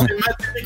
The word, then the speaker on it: maté: Ah, ah, non, c'est maté qu'on prononce exactement. – Ah, c'est maté, mate.